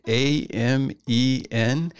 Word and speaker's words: Amen: By the way, when you see that word, A M E N, the way to pronounce it is Amen. You'll often hear people say amen, which is common in American English, but amen is closer to the right sound, so give it a try.